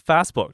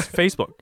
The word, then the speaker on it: Facebook: 哎呀，他就说：“ 可是 it's just not Facebook, it's Facebook，就是 no。”